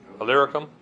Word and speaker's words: Illyricum: Illyricum. – Illyricum.